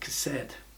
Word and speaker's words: chesed: Love here is the Hebrew word pronounced something like chesed.